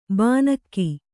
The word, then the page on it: ಬಾನಕ್ಕಿ: bānakki